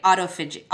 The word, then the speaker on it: autophagy: I think a great place to start is to talk about, and I hope I'm saying this right, autophagy? – Yes, you did a great job.